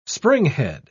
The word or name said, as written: springhead